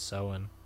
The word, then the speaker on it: Samhain: So, but apparently it originates from Celtic times when Halloween was called Samhain. Hmm. Am I saying that right? Samhain? – I think it's Samhain. – Samhain, which is spelled S-A-M-H-A-I-N. But apparently the apples were associated with love and fertility, so...